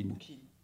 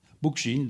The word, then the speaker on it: Bookchin: Bookchin. – Comment ? – Bookchin, donc ça va, ça.